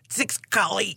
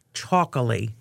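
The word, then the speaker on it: txakoli: It looks like it would be pronounced Tsikali. – Right. It's T-X-A-K-O-L-I-E. Choccoli.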